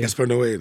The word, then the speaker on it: Gaspar Noé: Yeah, so just very, very short for those who don't know, Enter the Void is this crazy film made by, I don't know how you pronounce his name, Gaspar Noé. – Gaspar Noé is exactly right.